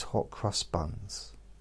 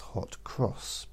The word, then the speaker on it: hot cross buns: And I had these hot crust buns. And I call them hot crust buns because that's not the correct way to say it. It's hot cross because they've got crosses on them.